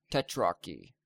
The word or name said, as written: tetrarchy